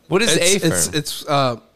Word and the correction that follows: Affirm: It's equivalent to, what's that one service that lets you buy? – Oh, shit, dude. – It's pronounced Affirm, you weirdo.